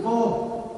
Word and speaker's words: vô: Cái chữ mô á, chữ mô nó nguyên chữ Hán nó đọc là chữ vô.